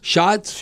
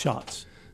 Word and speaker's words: Schatz: Aaron Schatz, C-H-A-T-Z. – Yeah. Shots. – Shots.